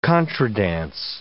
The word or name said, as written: contra dance